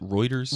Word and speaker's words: Reuters: Reuters? – Reuters, yeah. Reuters. He attended Eton, is it E-T-O-N?